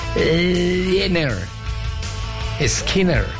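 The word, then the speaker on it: Lynyrd Skynyrd: Y la banda, ¿cómo se pronuncia? Leonard Skinner.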